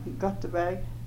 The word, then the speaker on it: Gotteberg: Gerda Gutterberg Fagerstrand. – Okay, would you spell that, please? – G-E-R-D-A, and my maiden name was Gutterberg, G-O-T-T-E-B-E-R-G, and my married name is Fagerstrand, F-A-R-E-S-T-R-A-N-D.